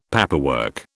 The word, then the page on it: paperwork: British English